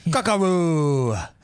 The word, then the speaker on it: Kakarot: Does the T silent? – Kakarot. That's how it's said.